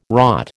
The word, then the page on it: rot: American English